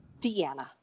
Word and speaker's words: Deanna: Deanna.